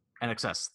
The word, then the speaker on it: INXS: INXS.